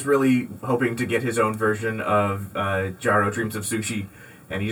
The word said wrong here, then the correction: Jiro: Yeah, it's Jiro Dreams of Sushi.